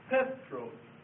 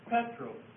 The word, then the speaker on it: Petros: Notice he's put the accent mark on the first syllable, and that's exactly how it is in the Greek. This is an accent mark in the Greek, and that tells you that the stress goes on the first syllable. So it's Petros, that's how you pronounce it.